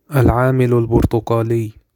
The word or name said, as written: Agent Orange